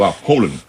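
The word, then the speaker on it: Haaland: นะครับคนนอร์เวย์ต้องเสียงว่าโฮลัน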